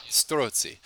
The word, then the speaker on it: Strozzi: Now, this actually led to the death of an innocent man who was acting as the intermediary between these two, one Ercole Storzi. – Storzi. – Yeah, that's why you're here, man. You hear that? That's my BS Italian pronunciations. Strozzi. So,